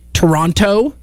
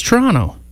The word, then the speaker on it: Toronto: But it's the same as people trying to pronounce Toronto. They say Toronto. It's Toronto. Or our province.